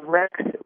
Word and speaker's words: Rechts: Yes, it's called Rex Regulat, and it's spelled R-E-C-H-T-S. It's from Germany, so that's why it's got kind of a strange name. And then the Latin part of it is R-E-G-U-L-A-T, Regulat.